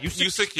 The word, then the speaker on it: Usyk: Usyk, yeah.